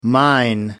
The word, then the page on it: mine: American English